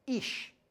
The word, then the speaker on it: ish: In Hebrew, man is pronounced ish. Ish is the Hebrew word for man. Guess what the Hebrew word for woman is?